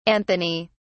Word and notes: anthony: British English

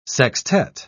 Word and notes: sextette: British English

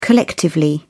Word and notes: collectively: British English

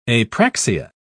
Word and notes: apraxia: British English

